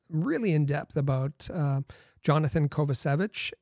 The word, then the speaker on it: Kovacevic: But he said it's Kovacevic. That's how his name. He wishes it to be pronounced.